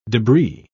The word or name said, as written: debris